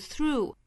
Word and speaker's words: through: 注意这句话里面的两个单词，一个是 through，一个是 thought，都是需要把舌头伸到口腔外面去摩擦上排牙齿发 th 的发音。